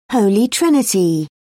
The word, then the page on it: holy trinity: British English